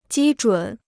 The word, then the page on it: 基准: jī zhǔn